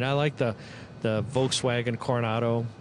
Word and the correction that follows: Corrado: Corrado, excuse me.